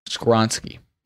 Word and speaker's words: Skoronski: Yeah, that's how you say his name. Skoronski.